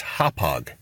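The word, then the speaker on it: Hauppauge: "Gentlemen, the correct pronunciation for Hopog, New York is Hopog."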